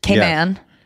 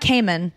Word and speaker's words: Cayman: Cayman. – Yes. Yes. – Okay, man. – Okay, man. – That's how I learned how to say it. So it's not Cayman, it's Cayman.